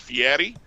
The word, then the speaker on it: Fieri: I would kind of go more towards like Fieri. – Mm-hmm. – Like, try and really. It's a rolled R, but he really hits it like D.